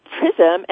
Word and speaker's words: PRISSAM: Well, PRISM, and by the way, it's spelled P-R-I-S-S-A-M, and I'll explain the terminology of that.